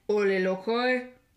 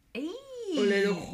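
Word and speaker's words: Ole Lukøje: Like LaCroix? – I was close. – What is it? – Ole Lucroix. – Ole Lucroix. You did really good.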